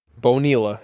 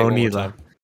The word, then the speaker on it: Bonilla: Bonilla. Yeah, say it right. – Bonilla.